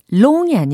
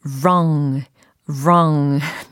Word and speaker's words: wrong: 제가 보니까는 이 wrong 발음을 long이 아니라 wrong이에요. 제가 설명을 드렸더니 우엉 이렇게 연습하시는 분들도 계시더라고요. 어, 우엉 센스 있으십니다. 근데 우엉은 아니고 wrong, wrong.